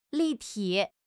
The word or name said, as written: 立体